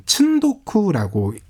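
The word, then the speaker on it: 츤도쿠라고 (積読): 이 적독의 의미로 이제 두 번째 이야기가 이어지는데 이 적독을 일본어로는 츤도쿠라고 읽거든요.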